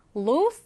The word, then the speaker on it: lose: 얘를 발음할 때는 좀 조심해 주셔야 합니다. lose 아니고요. lose. 굉장히 길게 발음을 합니다. lose. 그리고 이 s는 z 발음으로 한다는 것 기억해 두세요.